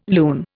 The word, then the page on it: loon: Indian